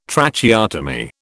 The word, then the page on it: Tracheotomy: British English